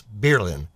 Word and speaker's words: Berlin: Berlin. So we don't care how you say it, Berlin, or Berlin, that's fine with us as long as you say it with friendship and affection.